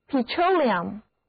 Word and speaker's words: petroleum: 那我们刚才说了汽油是从石油中提炼出来的，所以接下来我们就要看看石油这个单词 petroleum Petroleum, P-E-T-R-O-L-E-U-M, 这个字母 E 要读作短的 E, 而字母 O 要读它的本音 O, 字母组合 E-U 在这里读作双元音 E-R, Petroleum, 重音在第二个音节，一起来读一遍吧 ,Petroleum。